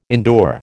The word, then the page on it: endure: American English